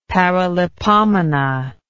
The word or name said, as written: paralipomena